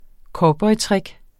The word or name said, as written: cowboytrick